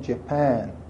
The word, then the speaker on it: Japan: فرانسوی ها میگن ژاپن، اما انگلیس ها میگن جپن.